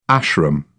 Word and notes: ashram: British English

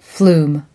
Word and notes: flume: American English

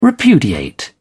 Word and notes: repudiate: British English

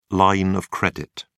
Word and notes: line of credit: British English

